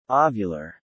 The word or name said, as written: ovular